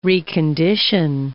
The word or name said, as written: recondition